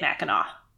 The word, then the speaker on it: Mackinac: Chris rang in and said, what is Mackinac Island? Which you would think that's how it's pronounced because it does end with a C, but they say it Mackinac.